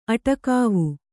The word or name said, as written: ಅಟಕಾವು